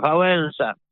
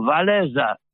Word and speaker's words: Wałęsa: Padały słynne niegdyś nazwiska Gieremek, Seweryn, Wajda, Wałęsa. Francuzi nazwisko Trybuna Robotniczego wymawiają Waleza, co dla ucha polskiego brzmi prawie jak Walezy.